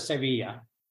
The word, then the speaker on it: Sevilla: They pronounce it Sevilla, and then so Sevilla? It's with an A, but they put an ill an E on the end, so it's Seville for you know foreigners, and I think the French use an E.